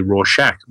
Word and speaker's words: Rorschach: And I went, oh, I've been saying it wrong all this time. It's actually Rorschach.